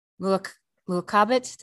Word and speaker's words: Mu'aqabat: still a figure in Islam, but maybe, you know, she's not a saint, but maybe she is the Mu'aqabat. Did I say that right? – Mm-hmm. Yeah.